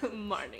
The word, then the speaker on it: Marnie: Today we're talking about 1964 Marnie. I don't know why I said it like that, but yes. – It is one word, one name, two syllables. Marnie.